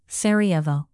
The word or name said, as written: sarajevo